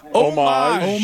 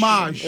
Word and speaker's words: homage: Well, the H is silent. – Is it homage? – Yep. – All right, okay. – Homage. – Homage.